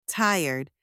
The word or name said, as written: tired